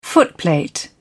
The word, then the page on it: footplate: British English